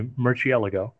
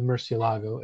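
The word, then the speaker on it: Murciélago: That the Murcielago, apparently as it's supposed to be pronounced, that was the last non-VW supervised Lamborghini. – That is correct. – Okay. – Yeah, because it was the R&D for the Murcielago, as I will pronounce it, because I'm just that way.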